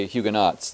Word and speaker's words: Huguenots: Last week, Jerry asked me about Huguenots or Huguenots, and I found a dictionary that had the T pronounced, Jerry, so I'll say Huguenots.